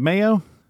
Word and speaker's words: Maio: Is it Maio? – It's Maio. You got it.